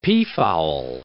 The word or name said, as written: peafowl